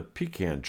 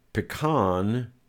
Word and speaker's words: pecan: Later, and I'm not quite sure how he did it, Daddy tied a rope into one of the pecan trees, pronounced pecan, for those of you not from North Carolina. Pecan is for snooty people, and pecan is the way we say it down south.